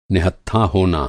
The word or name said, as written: निहत्था